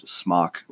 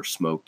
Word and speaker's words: SMOK: People start out with like the normal brands, Smok or Smoke, depending upon how you say it. It's SMOK.